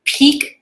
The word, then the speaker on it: Pique: Peak Tea, it's spelled P-I-Q-U-E,